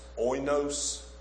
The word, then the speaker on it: oinos: The word for wine here, I believe, is pronounced oinos, O-I-N-O-S, and that term in and of itself doesn't tell you anything about whether it's alcoholic or not.